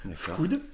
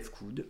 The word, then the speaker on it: Fkoood: on le prononce, enfin moi personnellement je le prononce d'une traite, Fcood. – Euh, mais tu peux le prononcer F-Coud. Euh, chacun, euh, chacun peut le prononcer comme il veut.